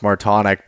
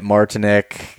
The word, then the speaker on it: Martonic: My last name is pronounced Martonic, but yeah, I usually get Martinic, just about any different way you can put those syllables together and pronounce it.